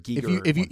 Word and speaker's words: Giger: I said Geiger for the longest time, and then I heard Giger.